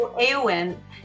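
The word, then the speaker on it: Éowyn: Arwen or Eowyn? Am I saying that right? – Eowyn.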